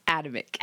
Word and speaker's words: Adamic: Did I just say it wrong? – Adamic. – Adamic. Oh, we practiced three or four times because it looks completely different.